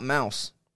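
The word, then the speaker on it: Maus: What about Mouse? – Mouse. – But it's not spelled the same like you think.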